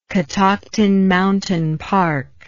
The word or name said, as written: Catoctin Mountain Park